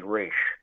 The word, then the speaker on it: Ra's: Because he's calling him Ra's, and she's like, you know, everybody kind of mistakenly calls him that, but it's really Ra's. – Yeah. A wink at the audience. Guys, here's how it's said.